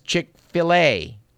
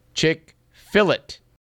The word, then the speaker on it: Chick-fil-A: It's Chick-fil-A, as in Chick-fil-A. According to our letter writer, Sharon, this restaurant originated in the South, and there was apparently a morbid fear that patrons would pronounce the restaurant Chick-fil-it if they spelled it F-I-L-E-T.